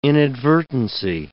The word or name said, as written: inadvertency